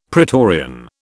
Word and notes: pretorian: British English

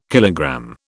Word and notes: kilogram: British English

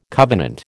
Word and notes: covenant: American English

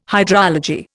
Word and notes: hydrology: Indian

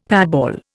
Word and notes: patball: Indian

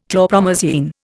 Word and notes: chlorpromazine: Indian

